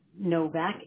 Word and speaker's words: Novak: You spell it K-A-R-E-Y. Is that correct? – Correct. Yes. – Okay. And your last name? – Novak, N-O-V-A-K, V as in Victor.